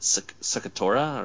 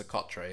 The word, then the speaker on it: Socotra: Is it Socotra or Socotra? – We get it wrong every time. I think it's Socotra, yeah. – Socotra.